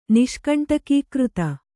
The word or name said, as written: ನಿಷ್ಕಂಟಕೀಕೃತ